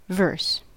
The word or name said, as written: verse